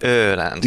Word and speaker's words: Öland: and then this big long island called Öland. – Mm. – And so we visited there where her cousins have a summer house. – Nicely pronounced Öland.